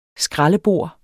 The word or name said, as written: skraldebor